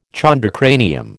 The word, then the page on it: chondrocranium: American English